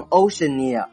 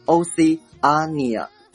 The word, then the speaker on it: Oceania: 这里面我强调一下发音，不要顺着 ocean 读下来，读成 Oceania，它读作 Oceania。